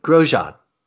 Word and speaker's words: Grosjean: Isn't that how you say it? – Grosjean. – That's what I said, isn't it? Dijon, Grosjean. – So, yeah, you're kind of. It's closer.